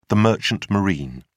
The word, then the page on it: merchant marine: American English